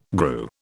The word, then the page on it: grew: British English